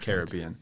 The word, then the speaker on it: Caribbean: Caribbean, I say now, because I know a Jamaican guy lives here, and he's very pointedly was like, it's pronounced Caribbean.